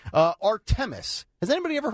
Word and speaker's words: Artemis: Artemis.